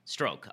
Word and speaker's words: Strzok: I think that's how it's pronounced. S-T-R-Z-O-K. Peter Strzok? Strzok? Stroke.